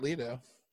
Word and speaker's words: Leto: Well, I mean, if you're going to be addicted to heroin, it helps if you're like Jared Leto. – Why does it help to be Jared Leto? There's like a... – Excuse me, it's Leto.